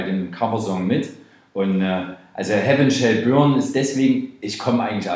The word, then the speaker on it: Heaven Shall Burn: Wir haben hier noch, jetzt muss ich es mal, Heaven Shall Burn. Ja.